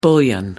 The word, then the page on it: bullion: American English